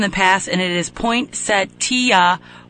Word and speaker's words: poinsettia: And so since you pronounce all the letters, it is poinsettia. – Says you. Wait. – We have tweets and Facebooks about poinsettia and pronunciation from producer Gregory Alexander of the Hal Spark Show.